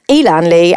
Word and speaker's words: Elan Lee: But, yeah, so the team of Ellen Lee, or Elan, perhaps. Perhaps yeah. – I pronounced that wrong. Elan Lee and Matthew Inman, who is the Mr. Oatmeal.